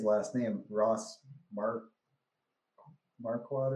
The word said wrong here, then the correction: Marquand: or whatever that Ross Marquand.